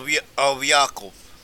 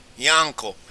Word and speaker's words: Yaakov: And the word Yankee, used for a nickname for an American, Yankee also comes from a Hebrew pronunciation of Yaakov. Yaakov. Yank is short for Yaakov, is short for Jacob.